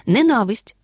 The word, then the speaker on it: ненависть: Ненависть Пиши разом, бо без частки не слово ми не вживаємо. Наголос падає на другий склад ненависть.